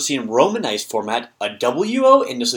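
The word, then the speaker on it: wo (を): That wo sound, the W sound at the beginning, indicates the funny little thing that looks like a T and a C, kind of had a baby.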